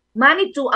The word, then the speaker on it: Manitou: Manitou ang tawag sa isang supernatural force among the Algonquian groups sa Native American theology. At sinasabing, no? So Manitou sa Ingles, pero ang pronunciation na Monetu, Monetu.